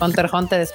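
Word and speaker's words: Hunter × Hunter: Usted dígale como quiera, joven, pero, pero las X no se pronuncian. Es Hunter Hunter.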